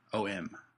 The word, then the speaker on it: phenom: Do you know, like, from what I can tell, the proper pronunciation is phenom.